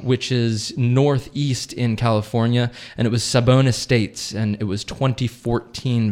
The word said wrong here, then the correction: Sobon: It's Sobon, S-O-B-O-N, Estates. And